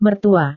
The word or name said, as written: mertua